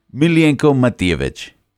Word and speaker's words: Milenko Matijevic: Milenko Matijevic.